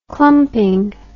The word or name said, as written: clumping